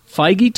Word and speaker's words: Feige: Feige told Gunn that he's concerned about revealing...